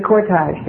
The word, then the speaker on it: cortege: And he said he remembered watching the funeral cortages, what do you say, going to... Hi there. Mr. Pronounce that word. The cortege. Thank you.